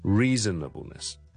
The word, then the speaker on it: Reasonableness: 再给多个例子大家，譬如一个法律的一个专用名词，我们叫做 reasonableness，合理的，譬如做这件事有没有这个所谓的 reasonableness 呢，是不是合理的呢？ R-E-A-S-O-N-A-B-L-E-N-E-S-S Reasonableness， Reasonableness 五个 syllables，但是一个重音，一个重音就是 Riddle， Reasonableness， Reasonableness。